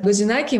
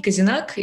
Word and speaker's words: гозинаки: Гозинаки мы готовим на Новый год всегда. А у нас именно так произносится, да, то есть гозинаки. на грузинском, не казинак и так далее.